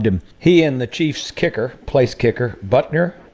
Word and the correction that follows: Butker: I think that's how you say it. – Yep, Butker.